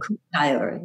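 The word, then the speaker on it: Kuuk Thaayorre: I'm really interested in this, how do you pronounce it? Kaktayor people. – Kaktayor, yeah.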